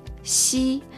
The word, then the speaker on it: xi: Yuan chuan, 圆圈 chuen, Seguimos con la tercera consonante de hoy, xi. Se escribe de la misma forma que la X en español, sin embargo se pronuncia un poco parecida a la S y un poco a la SH en español, pero se dice xi, si.